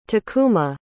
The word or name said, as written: takuma